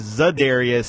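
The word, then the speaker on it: Za'Darius: They signed uh, Oladarius Smith from uh, the Ravens and Press. No, Oladarius. Say his name wrong, right? – Lada- no, no, yeah, uh, you're right. It is Zadarius Smith? – I'll oh, say Zadarius Smith, and yeah.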